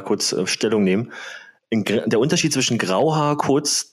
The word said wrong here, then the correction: Rauhaar: Ach, Rauhaar.